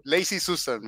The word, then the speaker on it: Lazy Susan: Ok, Lazy Susan, me corrijo.